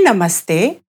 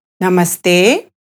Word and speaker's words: Namaste: Listener, I'll say Namaste and then there will be a brief pause for you to repeat. Okay, here we go. Namaste.